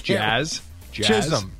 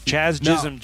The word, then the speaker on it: Jazz Chisholm: Jazz? – Chisholm. – There you go. Good job. – No, isn't it Chaz? – Chaz Chisholm. – No. – Jazz Chisholm. Boy.